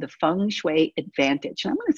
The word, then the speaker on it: Feng: It's F-E-N-G, but it's pronounced Feng. So it's F-E-N-G. Then Shui is spelled S-H-U-I.